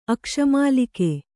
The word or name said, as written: ಆಕಾಶಮಲ್ಲಿಗೆ